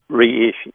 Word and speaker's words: reishi: This reishi mushroom that you're saying, how do you spell that? – Reishi, reishi. – R-E-I-S-H-I.